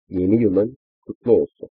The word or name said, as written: yeni yilin kutlu olsun